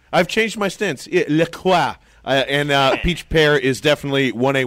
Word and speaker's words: LaCroix: LaCroix. It's a French word. It's pronounced LaCroix. No, listen, here's the thing. You are 100% correct because I call it that forever. However, the website does say LaCroix, and it annoys the hell out of me.